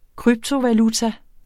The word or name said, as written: kryptovaluta